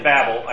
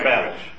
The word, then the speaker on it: Babel: You can pronounce it Babel. I actually looked it up in Hebrew, and you can put the accent either way. So Babel or Babel.